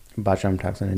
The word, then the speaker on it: botulinum toxin: And so that's a popular reason to get botulinum toxin injected.